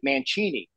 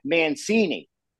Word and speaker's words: Mancini: So when my grandparents came from Italy, right, from 1921, in Italy, they pronounce it Mancini. But when she got here, it was like Mancini. So that's how it's pronounced.